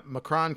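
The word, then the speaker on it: M'Kraan: Uh, and they're working for Magneto, and they're trying to find uh, the Mcron uh, or the Macran. I never knew how to say it. I said Mcron, but it's pronounced M apostrophe K R A A N. The uh, Macron crystal.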